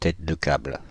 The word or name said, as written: tête de câble